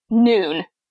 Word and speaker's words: Nuun: She's like, I have this, bottle of Noon is what it's called. That's spelled N-U-U-N. That's why no one knows how to pronounce it.